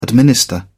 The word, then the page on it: administer: British English